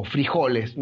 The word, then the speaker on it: frijoles: El Bonanza es un juego de negociación, donde tú tienes que negociar, y acá te vas a reír, porotos o frijoles, no sé cómo les dicen en Colombia. – Frijoles. Nosotros le ponemos acento en la I, los mexicanos en la O.